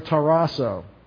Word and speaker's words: tarasso: I want to look at this word, tarasso. T-A-R-A-S-S-O. Long O. Tarasso.